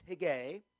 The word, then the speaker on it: Hegai: it's pronounced Higgai, that Esther was brought also unto the king's house to the custody of Higgai, the keeper of the women.